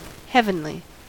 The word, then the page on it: heavenly: American English